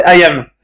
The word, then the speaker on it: Haïm: C'est-à-dire c'était Saban qui était derrière, Haïm Saban, Haïm, je sais pas trop comment on prononce. – Non c'est Ayam.